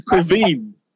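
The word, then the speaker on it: Caoimhín: How do you say his first name? – I don't know, and I don't care. – They got the Irish crew. It's Quiveen.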